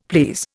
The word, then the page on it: please: Indian